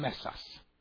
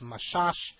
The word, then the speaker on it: Mesas: We are now looking at, here's a picture of Rav Shalom Mesas. In Israel, his name is often pronounced Mashash. If he came then in Israel, in Morocco, it was Mesas.